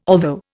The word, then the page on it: although: Indian